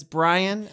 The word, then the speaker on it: Brian: And it's Brian with an I. Thank you so much. And my name is not Brain. People spell it wrong all the time. And I think I'm smart, but my name is Brian. – B-R-I-A-N, people.